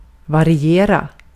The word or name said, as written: variera